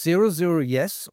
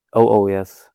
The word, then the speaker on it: Oh Oh Yes: Eure zweite LP, wie spricht die sich aus? Zero Zero Yes oder Oh Oh Yes oder wie? – Oh Oh Yes, haben wir die eben ausgesprochen.